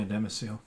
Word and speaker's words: domicile: So it's domicile, I think that's how you pronounce it. – Yeah, domicile. Yeah.